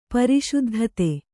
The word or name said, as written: ಪ್ರಸಿದ್ಧತೆ